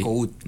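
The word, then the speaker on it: Nacoud: Mira, mis redes sociales es mi nombre, Omar Nacut. – Ok, ¿qué se escribe? – Se escribe N-A-C de casa O-U-D de dedo, okay. Nacut.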